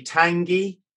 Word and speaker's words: Tangi: Just because that's the only entertainment I have in life now, but they give it, and it's Tangi, Tangai, and then they always spell it wrong anyway. But Tangi, yes, as in a hot sauce.